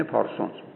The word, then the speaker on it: Parsons: پارسونز یعنی P-A-R-S-O-N-S، اگر تلفظ انگلیسیش میخواید اینه پارسونز.